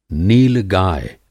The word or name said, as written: नीलगाय